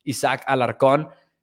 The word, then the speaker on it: Alarcón: I will pronounce it as it really should be pronounced, Isaac Alarcón. Isaac is getting reps at tackle out there in Oxnard.